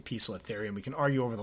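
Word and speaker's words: Poecilotheria: I was informed that that's how you say it. I know people say Pocolotharia, and obviously we call them Pokies. I do believe that's because of how it's mispronounced.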